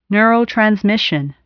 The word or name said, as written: neurotransmission